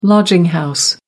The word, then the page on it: lodging house: American English